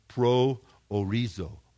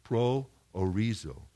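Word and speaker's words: proorizō: And the second part of the word is horizo. We get the word horizon from it. Horizo, horizon. You guys get the transliteration there, okay? So this word pro orizo is only used 6 times in the New Testament.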